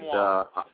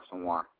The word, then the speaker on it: Asomugha: Uh, it's also Mugo, I believe that's how you pronounce him, uh, his name, uh, who defended Asomugha. – uh, Asomugha, uh, who defended go.